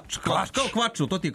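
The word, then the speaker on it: Clutch: Idemo na slovo C, stigli smo do slova C, a tamo, kako se izgovara K, tako, klač.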